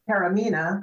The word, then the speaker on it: Taormina: Taormina, but it's next to Mount Etna.